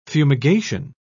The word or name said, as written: fumigation